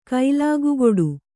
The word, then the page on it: ಕೈಲಾಗುಗೊಡು: kailāgugoḍu